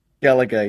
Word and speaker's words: Gallagher: Gallagher, yep.